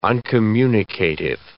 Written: uncommunicative

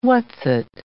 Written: whatsit

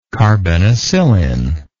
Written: carbenicillin

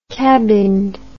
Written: cabined

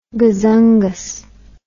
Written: gazungas

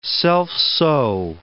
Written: self-sow

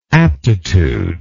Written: aptitudinally

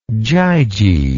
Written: gyges

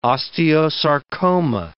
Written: osteosarcoma

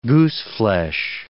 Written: gooseflesh

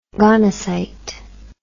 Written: gonocyte

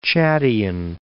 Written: Chadian

